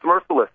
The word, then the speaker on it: Mercilus: Are, are we pronouncing it right or is it Mercilus? – No, it's Mercilus.